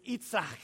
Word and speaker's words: Yitzhak: In Hebrew, it's Yitzhak. We pronounce it Isaac.